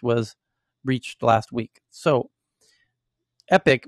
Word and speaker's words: EPIK: So the next one is in a live Q&A, the CEO of EPIK, which is pronounced Epic, but not to be confused with the gaming company.